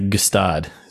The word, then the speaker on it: Gstaad: It's got a silent G at the front. – Yes. – Unbelievably. – It's not, you know, I'd pronounce that Gstaad.